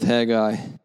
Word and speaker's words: Haggai: Bibles to the prophet Haggai, whose name pronunciation is, of course, infamous. Michael and I had a discussion this week about how to pronounce his name, but we're going to go with Haggai this morning.